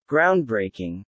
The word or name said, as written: groundbreaking